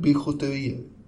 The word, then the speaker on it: bijouterier: Bioterier. Hur uttalas det? Eh, bijouterier. – Ja, där har du det.